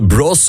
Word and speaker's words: Bros: Maar die heeft dus Bros, want zo moet je het eigenlijk echt uitspreken.